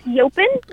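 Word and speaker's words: yaupon: It's Yopin. At least that's how they say it on Ocracoke, Okay.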